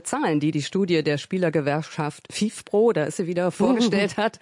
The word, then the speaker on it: FIFPro: Wie spricht sich das aus? – FIFPro ist komplett richtig.